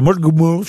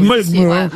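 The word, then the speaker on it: Mullaghmore: Une bombe télécommandée de 23 kilos a été placée près du moteur de l'embarcation dans une baie irlandaise à Mulgmul. Je sais pas si je prononce bien. – Non, c'est très bien prononcé. – Mulgmul.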